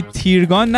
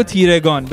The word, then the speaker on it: تیرگان: به علاوه این که یه ایمیل جالب داشتیم از دوست عزیزمون مهربان منوچهری که گفته بود این وقتی میگی تیرگان نگو تیرگان، بگو تیرگان، بگو تیرگان. – به خاطر که وقت یادم به سیاهی و تیرگی میفته دلم میگیره، و درست میگه، تلفظ صحیحش تیرگانه، اگرم من گفتم تیرگان معذرت میخوام.